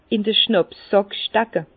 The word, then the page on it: in d'r Schnàpsssàck stecke: Bas-Rhin; Herrlisheim